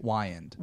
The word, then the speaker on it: Wiand: Wiand. – Wiand. Cole yeah. Wiand. – It's Wiand.